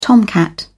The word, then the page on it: tomcat: British English